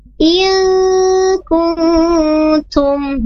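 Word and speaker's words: il kuntum (إن كنتم): Tadinya Ibu Karmi ini bacanya in kuntum, tapi yang tepatnya il kuntum. Nah, dia di sana ikhfa, bukan izhar ya.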